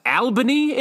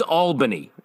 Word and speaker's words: Albany: Pronounced Albany in this show, which I think us upstate boys know. It's Albany.